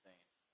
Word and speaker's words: Zane: The reason why that is because my name is Zane. It's kind of, there's a huge story behind the name. People have always messed up my name, just calling me Dan, Z-A-N. They didn't ever pronounce the E.